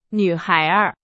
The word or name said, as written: 女孩儿